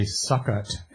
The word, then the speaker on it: Sukkot: or your calendar may say Sukkot, S-U-K-K-O-T. Some people may pronounce it Sukkot.